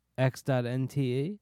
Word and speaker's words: x.nte: Now, the name of this project is x.nte. How do you say that? – Are you sure? – Yeah, I'm positive. – I think you say it just like you did. – x.nte? – Yeah.